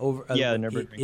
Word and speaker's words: Nürburgring: Nürburgring. Did I say that right, gentlemen? – The Nürburgring is going to kind of blow everything else out of the water.